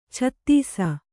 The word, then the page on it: ಛತ್ತೀಸ: chattīsa